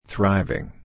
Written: thriving

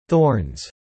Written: thorns

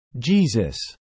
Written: Jesus

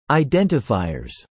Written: identifiers

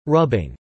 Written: rubbing

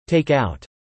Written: Take-out

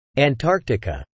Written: Antarctica

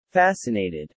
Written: fascinated

